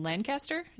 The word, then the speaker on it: Lancaster: And I learned that he was born in Massachusetts, in Lancaster.